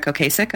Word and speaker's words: Ocasek: Oh, and it was produced by, I'm not going to pronounce his last name correctly, Rick Ocasek of The Cars. – I believe that's correct.